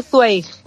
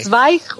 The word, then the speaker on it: Zweig: Estuviste con el 24 horas para la vida de una mujer, la obra de Stefan Zweig, ¿cómo se dice? – Zweig. También se puede decir Zweig, vale. – Zweig, como tú quieras.